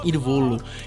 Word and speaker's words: Il Volo: Mas quando a gente fala sobre bandas italianas, o Irvolo é uma eu acho que eu tô pronunciando certo, uhum. né? Irvolo. – Irvolo É uma banda que o pessoal costuma comentar bastante.